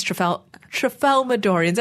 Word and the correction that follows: Tralfamadorian: Am I even saying this correctly? – I kept stumbling. Trafalmadorian? – Well, I mean, I called you that. Tramalfadorian? – Tralfamador is the planet.